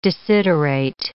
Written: desiderate

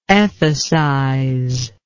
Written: ethicize

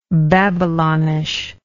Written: Babylonish

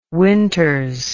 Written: winters

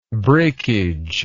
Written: brakeage

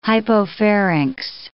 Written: hypopharynx

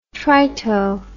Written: trito

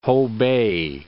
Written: Hopei

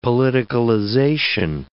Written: politicalization

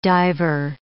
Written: diver

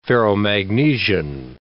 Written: ferromagnesian

